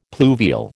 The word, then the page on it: pluvial: American English